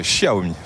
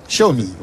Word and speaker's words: Xiaomi: Avec la prononciation de Xiaomi aussi. Tout à fait, Xiaomi. Xiao, Xiaomi, voilà.